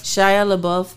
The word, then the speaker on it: Shia LaBeouf: Shia LaBeouf.